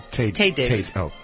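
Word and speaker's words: Taye Diggs: She and her husband, Ty Diggs, did a duet. Tay Diggs.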